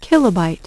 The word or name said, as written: kilobyte